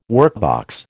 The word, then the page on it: workbox: American English